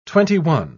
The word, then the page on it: twenty-one: British English